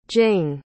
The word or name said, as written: jing